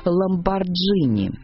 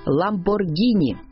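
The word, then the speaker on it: Lamborghini: Привык стоять за себя и наверняка не потерпел бы, если его самого или любимой машины кто-то сгоряча назвал «Ламборджини». Тем не менее, российские фанаты суперкаров зачастую говорят именно так, хотя никаких доводов в пользу произношения через G нет. Запомним, по правилам итальянского языка «Ламборгини» и только так.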